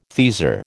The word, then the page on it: teaser: American English